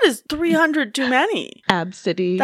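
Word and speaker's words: ABCDE: And it's pronounced Absidy. Absidy, yep.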